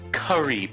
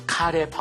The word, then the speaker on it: curry: We have onion, green onion, 양파, 파, 알새우, shrimp, no skin, no shell, rice, 밥, curry powder, 네, 그 카레 파우더, 이거 순한 맛, mild curry powder, 한국어 발음은 카레, 영어 발음은 curry.